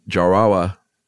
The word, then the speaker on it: Jarawa: Notably in India's Andaman Island, the Jarawa. Mm-hmm. It sounds like I'm saying that wrong, but that's totally right.